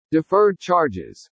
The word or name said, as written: DEFERRED CHARGES